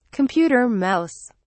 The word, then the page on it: computer mouse: British English